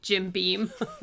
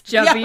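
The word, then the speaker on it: Jabiim: And they had also referenced that Andor was released from prison to the planet we can never pronounce that we always say Jim Beam. – Yes. – Jabim.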